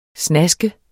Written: snask

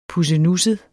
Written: pussenusset

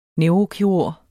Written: neurokirurg

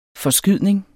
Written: FORSKYDNING

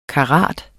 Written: karat

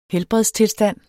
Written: helbredstilstand

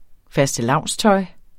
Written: fastelavnstøj

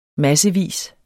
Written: massevis

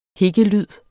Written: hikkelyd

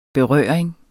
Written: berøring

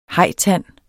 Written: hajtand

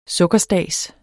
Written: sukkerstads

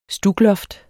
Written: stukloft

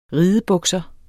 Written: ridebukser

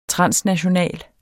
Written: transnational

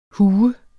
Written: hu

